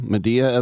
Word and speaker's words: Medea: Um, to the Me Made Medea Medea event fundraiser It'd be nice if I could say it right.